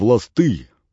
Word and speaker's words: ласты: «Мы предпочитаем перейти скорее в ласты, ударение на «ы», чем прогнуться по костам, ударение на «а», и тем самым позволить аутсорсить наш ресурс без достижения заложенного профита».